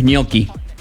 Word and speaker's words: gnocchi: Que la pronunciación de gnocchi en italiano es gnocchi.